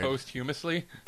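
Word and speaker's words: posthumously: Post-humorously. – If Allie hears this and she hears that I still haven't learned how to say the word. – Post-humously? – Thank you.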